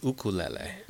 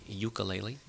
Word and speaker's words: ukulele: The Hawaiian pronunciation is ukulele. – Ukulele. – Ukulele. Here on the mainland, we say ukulele.